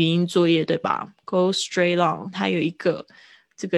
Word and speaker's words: straight: 嗯，呃，go straight on 就是一直继继续走，直直走。